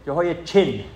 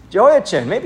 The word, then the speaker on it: Jehoiachin: Jehoiachin is how you say it. Jehoiachin.